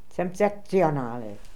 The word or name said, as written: Semtzektzionally